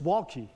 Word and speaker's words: Waltke: Bible commentator Walter, Walter, uh, Bruce Waltke. let me get his name right, Bruce Waltke notes that when a third day speaks to a significant preparation, that the, it was pre- a significant moment was about to happen.